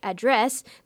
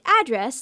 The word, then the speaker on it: address: Generally, if the stress is on the second syllable, like address, the word is a verb. If it's on the first syllable, like address, the word is a noun.